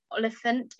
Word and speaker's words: Oliphant: My second one would probably be, I've just finished Eleanor Oliphant is Completely Fine. I think I've said that correctly.